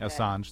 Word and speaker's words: Assange: So I think in WikiLeaks' stance, Julian Assange, I guess is the way you pronounce it. Assange, that's right.